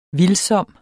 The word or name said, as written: VILDSOMT